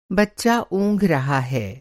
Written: ऊँघना

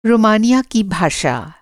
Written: रोमानिया

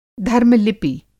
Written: धर्म-लिपि

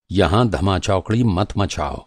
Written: धमा-चौकड़ी

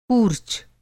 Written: कूर्च

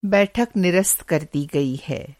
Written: निरस्त

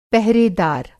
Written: पहरेदार

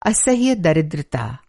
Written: असह्य

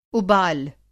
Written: उबाल

